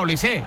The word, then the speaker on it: Olise: Es decir, si Mbappé es Mbappé, Dembélé es Dembélé y Benzema es Benzema, pues digo yo que Olise será Olise.